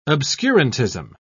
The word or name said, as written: obscurantism